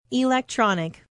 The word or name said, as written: electronic